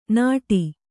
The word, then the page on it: ನಾಡಿ: nāḍi